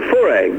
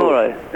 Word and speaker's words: Fauré: It's by Gabriel Fauré. Is that how you say it? – Oh, Fauré. Gabriel Fauré.